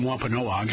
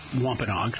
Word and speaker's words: Wampanoag: And first of all, I've been calling them Wampanoag, and I guess that's an incorrect pronunciation. It's Wampanoag. There is a difference,